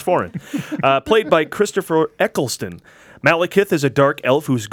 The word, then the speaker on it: Malekith: The villain this time around is Malekith.